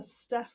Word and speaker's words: Steph: And I'm a Steph with a PH.